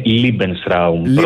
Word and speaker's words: Lebensraum: Lebensraum το έχουμε, είναι ακουστικά σε εμάς, αλλά η πιο σωστή προφορά είναι Lebensraum, προς η γερμανική εκφορά του λόγου.